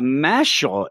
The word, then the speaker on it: Mashle: Yes, and we decided that we were going to do a Mashle, as I'm told it's pronounced, Magic and Muscles.